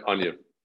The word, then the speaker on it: Anyu: You call her Anyu, is that how you pronounce That's it? right. Yep, Anyu.